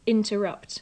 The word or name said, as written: interrupt